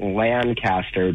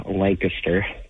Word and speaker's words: Lancaster: And I know you Californians say it Lancaster, but it's actually pronounced Lancaster.